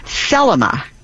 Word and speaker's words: Thelema: And what she was asking about is something called Thelema. T-H-E-L-E-M-A with the emphasis on the first syllable.